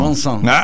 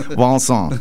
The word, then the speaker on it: Vincent: Hay muchos músicos que también han se han inspirado en obras, por ejemplo, de Magritte, de Van Gogh, o ¿cómo se dice? Vincent o Vincent. – Vincent. – En francés es Vincent.